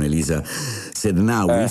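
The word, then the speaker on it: Elisa Sednaoui: Elisa Sednaui, si pronuncia così, che allora, nessuno può farti star male senza il tuo permesso, no? – Esatto.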